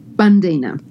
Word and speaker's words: Bundeena: Bundeena.